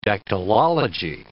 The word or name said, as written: dactylology